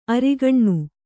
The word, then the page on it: ಆರಗಿನ: āragina